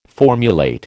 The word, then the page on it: formulate: American English